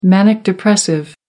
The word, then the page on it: manic depressive: American English